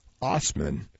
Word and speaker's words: Assman: Osman says his Germanic name is pronounced Osman, but officials said that's not something that would be apparent to other motorists. The spelling was A-S-S-M-A-N.